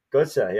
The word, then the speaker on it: Götze: How do you say his last name? – Götze.